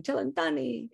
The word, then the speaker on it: Cellentani: I said that right. Yeah, it's Italian. Cellentani.